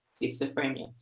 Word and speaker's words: schizophrenia: Yes, doctor, can you please explain for me what is paranoid schizophrenia? If I pronounce it correctly. – Yes, paranoid schizophrenia.